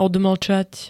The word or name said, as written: odmlčať